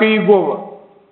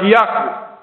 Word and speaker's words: Яхве: В русском синодальном переводе оно читается иногда как Иегова. Как Иегова. Но это неправильная система произношения этого имени, это ошибка. В действительности оно должно произноситься как Яхве.